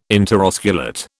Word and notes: interosculate: British English

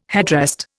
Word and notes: headrest: Indian

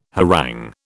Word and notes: harangue: British English

